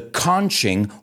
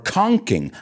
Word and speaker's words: conching: Well, he invented what is called the conching or conking. I've actually heard it pronounced both ways.